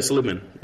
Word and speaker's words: Seligman: Is that how you pronounce, how do you pronounce her last name? Do you know? – Yeah, Seligman.